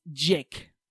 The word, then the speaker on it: JK: It's spelled JK, but it's pronounced Jake.